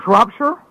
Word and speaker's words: Shropshire: John from Shropshire. And I'm probably mispronouncing. Shropshire? – Yeah, that's right.